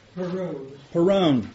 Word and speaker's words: Perowne: His last name is spelled Peroni, I think. No, that's not how it's spelled. It's pronounced P-E-R-O-W-N-E. Anyone help me out? – Perone.